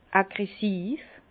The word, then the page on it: aggressiv: Bas-Rhin; Herrlisheim